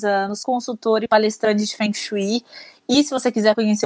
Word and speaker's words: Feng Shui: Em mandarim se pronuncia Feng Shui.